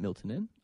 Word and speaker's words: Miltinan: Is it Miltinan? I'm like, that's exactly how you pronounce it.